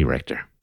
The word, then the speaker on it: Richter: It's Richter, right? Like Andy Richter.